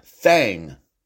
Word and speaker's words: Thang: Now, this next creature we're going to hear about it is the actual correct pronunciation of this creature. It's called the Alabama White Thang. T H A N G.